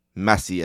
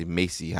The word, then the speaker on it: Messi: My accent makes it very hard to pronounce Messi as Messi for some reason. I always want to say Macy. I don't know why.